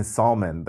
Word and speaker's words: salmon: You keep saying salmon. Like, you don't even have to pronounce the L.